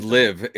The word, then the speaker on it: LIV: it's LIV. It rhymes with give.